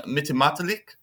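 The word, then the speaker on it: Mittimatalik: So our two guests are Justin Sigluk-Milton, who is an Inuk from Mitimatalik, I hope I pronounced that correctly, in Nunavut, now living in Ottawa, and he is the manager of IKARVIK. – Hi, everyone. Yeah, and you pronounced that perfectly.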